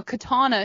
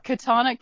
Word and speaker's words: katana: He's got a 300-page thesis, a katana, excuse me. I'm saying that wrong. – Yeah. – Katana. Jen got it.